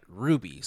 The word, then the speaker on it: RWBY: We're talking about Ruby, spelled R-W-B-Y.